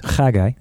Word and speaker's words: Haggai: Haggai, that's how you say his name.